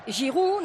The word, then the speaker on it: Giroud: Giroud, no se pronuncia la D. Y Rabiot, no, se dice Rabiot. Ay, madre. – Rabiot. – Qué vergüenza. – O sea, no se pronuncia ni la T de Rabiot, ni la D de Giroud.